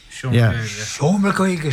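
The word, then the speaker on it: Seomra Gaeilge: Seomer Gaeilge, is that how you pronounce it? – Seomer Gaeilge. Yeah.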